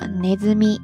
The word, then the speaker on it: 鼠: 这个单词后半部分的那个“ 鼠” 字读作 “nezumi”，自然意思就是老鼠了。